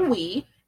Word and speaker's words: Huy: So, Bao has a friend over Wee. It's spelled H-U-Y, but I have a student named Wee, and that's how he pronounces it.